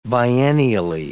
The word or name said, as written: biennially